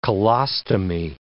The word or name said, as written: colostomy